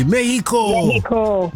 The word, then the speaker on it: Mexico: When you get there, don't pronounce the X the way that we do. Just say Mexico.